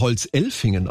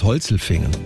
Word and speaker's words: Holzelfingen: Den würde man Holzelfingen aussprechen. Wegen der Elfen oben auf der Alp. Nee, der heißt Holzelfingen.